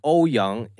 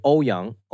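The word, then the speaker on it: Ouyang: In Mandarin, it would be O-Yang.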